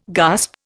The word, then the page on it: gasp: Indian